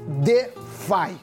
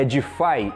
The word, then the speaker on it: DeFi: Porque hoje vamos falar sobre uma nova possibilidade de investimento, que eu não sei se já ouviram falar, que se chama DeFi. – Um bom começo é saber que a pronúncia correta é DeFi e não DEF.